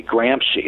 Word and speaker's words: Gramsci: Gramsci is spelled G-R-A-M-S-C-I, Gramsci. It's like Gramsci, but it's pronounced Gramsci.